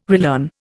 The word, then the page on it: relearn: Indian